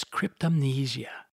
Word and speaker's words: cryptomnesia: It is known as cryptomnesia. You can Google this word and you will find that Webster's dictionary indicates that it is pronounced just this way.